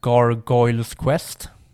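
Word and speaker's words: Gargoyle's Quest: Sen, typ, ja, jag vet inte om jag uttalar det här, Gargoyles Quest. Ja, helt rätt.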